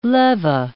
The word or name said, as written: leva